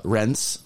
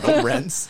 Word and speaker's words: Rennes: So, I was in Rennes for... Which I learned that's how you actually say it. – Mm-hmm. Cheers for the correct pronunciation. Very nicely done. – Fucking Reims. – Rennes.